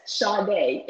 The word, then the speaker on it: Sade: Yes, Sade. It's spelled S-A-D-E. Sade.